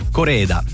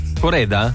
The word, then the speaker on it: Koreeda: Koreeda. – Koreeda? – Koreeda.